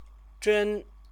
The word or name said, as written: zhen